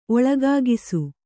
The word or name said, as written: ಒಳಗಾಗಿಸು